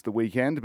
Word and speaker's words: The Weeknd: I'm pretty sure it's pronounced The Weeknd, but he spelt it wrong.